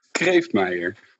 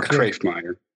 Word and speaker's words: Kreeftmeijer: That's almost it. It's Kreeftmeijer or Kreeftmeijer.